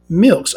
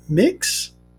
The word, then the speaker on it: Mx: Is a title, examples, Mr., Mrs., etc., that is gender neutral, pronounced milks. Okay, so it's pronounced milks? I can't be saying that right. Mix? Oh, mix. It's pronounced mix. So MX, mix, because I'm retarded. Okay, similar to miss, mix.